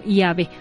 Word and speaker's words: Yahvé: Ellos no, ellos siguieron pronunciando este tetragrama y lo pronunciaban como Yahvé, ¿verdad? Entonces de ahí la iglesia lo retoma y dice, bueno, entonces si esta es la pronunciación que está más cercana a esos tiempos, pues bueno, es la pronunciación que ahora le damos y que le mencionamos Yahvé.